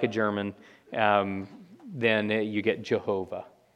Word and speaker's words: Jehovah: And so this is where we get the name that sometimes appears in English translations, Jehovah. Because that first Y in German is pronounced like a J, the W is pronounced like a V, you've got an H on either side of that, and in Hebrew you're only getting the consonants.